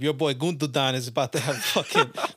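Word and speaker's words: Gündoğan: It is Gundogan. You said Gundogan.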